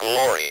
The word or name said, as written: glory